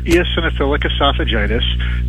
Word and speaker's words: eosinophilic esophagitis: So it stands for eosinophilic esophagitis, but we do abbreviate it EOE or else we'd be here all day talking about it.